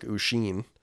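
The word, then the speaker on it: Oisín: He said it pronounced like Usheen. Are you sure that's how you say you say your name? Because it's O I S I N.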